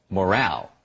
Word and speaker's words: morale: Now, notice that the stress is on the second syllable, morale.